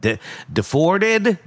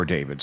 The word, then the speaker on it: D4VD: You've seen the D4VD and was like, what? Deforded? Deforvid? What? It's pronounced David.